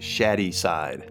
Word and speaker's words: Shadyside: It's pronounced Shadyside. – I'm not sure that's right. It's spelled shady.